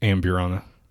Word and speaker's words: Amburana: Um, the Urbriana or Ambriana, however you want to pronounce Am- it. Amburana. Ambriana, yeah.